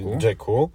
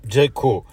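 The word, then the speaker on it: Jaecoo: a więc Omoda i Jayku, bo to się wymawia Jayku, a nie Jayku.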